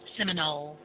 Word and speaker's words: Seminole: Seminole.